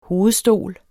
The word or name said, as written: hovedstol